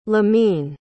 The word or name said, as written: limine